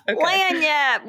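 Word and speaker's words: lagniappe: Yeah, we actually, so in our online classes, we do these extra things. I'm going to so pronounce this wrong. You're from Louisiana. – Lanyap.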